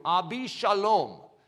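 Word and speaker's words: Abishalom (אבשלום): Absalom actually is pronounced in the Hebrew, Abishalom.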